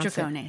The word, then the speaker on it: Dragones: Dragones.